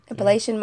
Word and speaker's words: Appalachian: It's about this Appalachian. Did I say that right? Appalachian yeah.